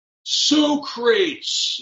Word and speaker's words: Socrates: Part two is when they go and basically hijack or kidnap historical figures, and Socrates is the correct spelling, or pronunciation, sorry. – Yes. – It's the same spelling, but it's Socrates.